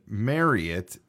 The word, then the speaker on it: Marriott: and their audition script said Marriott is actually pronounced like you're going to marry it, or chariot.